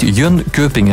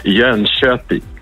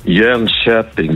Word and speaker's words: Jönköping: Het is niet Jönköping, hè? Dat is totaal verkeerd. Hè? Wat moeten we dan wel zeggen? – Ja, het is eigenlijk Jönköping. Jönköping. Dus de K voor de omlaag wordt heel zacht uitgesproken in Zweden.